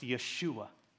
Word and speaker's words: Yeshua: In Hebrew, the name Jesus is often pronounced Yeshua.